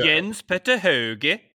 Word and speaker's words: Jens Petter Hauge: Jensen Petter Hauge, is that how you say his name? – But anyway, the guy who... Jens Petter Hauge.